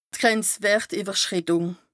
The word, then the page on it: Grenzwërtìwwerschrìttùng: Bas Rhin